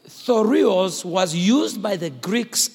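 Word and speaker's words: thureos: That word shield in the Greek, it's the word T-H-U-R-E-O-S. T-H-U-R-E-O-S, pronounced thorios. Thorios.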